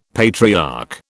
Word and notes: patriarch: British English

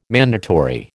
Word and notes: mandatory: American English

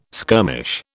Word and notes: skirmish: British English

